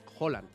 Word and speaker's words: Haaland: Entonces, Haaland. – No sé si lo lograremos mantener, pero se dice así. Nosotros, como Holanda, nosotros, Haaland.